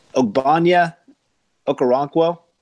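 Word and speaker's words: Ogbonnia Okoronkwo: Ogbonya Okoronkwo.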